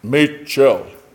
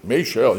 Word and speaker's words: Maschil: When you see M A S C H I L, that's not how I would think to pronounce it, but I looked it up. You can do this nowadays. You look it up and they say it. Machel. Machel. You're like, shell, Machel.